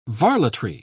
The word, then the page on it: varletry: British English